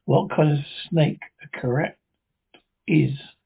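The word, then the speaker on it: krait: Tonight we escape to India, the story of a man trapped in his bed by corrupt of those deadly snake, poisonous snake in the world, as Roald Dahl tells it in his terrifying story, Poison.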